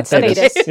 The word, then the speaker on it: Thedas: Thedas. – Yeah, Thedas. Yeah, you nailed it. Thedas.